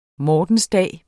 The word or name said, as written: mortensdag